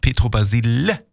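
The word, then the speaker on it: Pietro Basile: Und habe ich extra, ich wusste auch nicht, wie hat er nun ausgesprochen? Ja, ich habe bei Giovanni Zarella aufgepasst, aber auch nicht genau hingehört. Aber man hat mir gesagt, Petro Basile.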